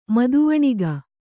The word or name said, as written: ಮದವಣಿಗ